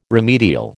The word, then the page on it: remedial: American English